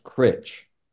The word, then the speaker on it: Cruit: Well, it's spelled C-R-U-I-T. – Okay. – But all the locals will tell you it's pronounced Critch.